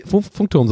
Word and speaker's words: Funkturm: Gut, aber oder mein Ansatz wäre, den in Berlin hinter dem Funktor, Funkturm so aufzustellen, dass einfach keiner so. Warum habe ich das so komisch ausgesprochen? – Funkturm.